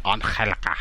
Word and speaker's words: Angelica: and Angelica. – Angelica. – Silent G. – Yeah, Silent G.